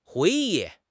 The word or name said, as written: hui2 yi4